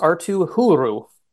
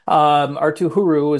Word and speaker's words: Arttu Heuru: and it's pronounced Arttu Heuru is how it's pronounced. Arttu Heuru is going to probably be in the mix to make the roster out of camp.